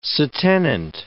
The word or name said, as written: se tenant